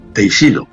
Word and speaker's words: Teixido: Hay un lugar en Galicia increíble, San Andrés de Teixido, o Teixidó, no sé cómo se dice. – Teixido. – Teixido.